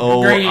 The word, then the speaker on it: Groening: But does anyone know how to say his last name? – It's Groening. – Groening? – Are you sure? – I believe I've heard it said Groening before. It's Groening. Ing.